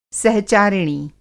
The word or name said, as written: सहचारिणी